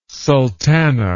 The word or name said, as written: sultana